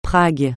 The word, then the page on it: Prague: francais